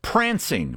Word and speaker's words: Pfrancing: And Prancing, but it's spelled P-F-R-A-N-C-I-N-G, in reference to his wife at the time, Francine.